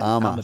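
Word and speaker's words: Ama Dablam: It was Mount Armadablam in 18. – Mount Armadablam. – Armadablam, yeah. – How do you say it? – AMA. – AMA, yeah. – Dablam, D-A-B-L-A-M. Dablam. So Armadablam.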